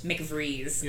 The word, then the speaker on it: McVries: We meet his friend, I don't know how to pronounce this. – McVries? – McVries.